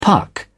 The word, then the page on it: puck: British English